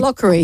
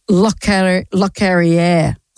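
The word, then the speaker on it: Locorriere: His Not Lockery. He has passed away. Let's say his name correctly, she said, looking for the piece of paper. Lockerrier.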